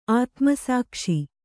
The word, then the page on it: ಆತ್ಮಸಾಕ್ಷಿ: ātmasākṣi